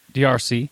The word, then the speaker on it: D'arce: Well, okay, your name, Joe, is pronounced DRC. And there's a choke that is a very similar pronunciation. People call it the Darce choke.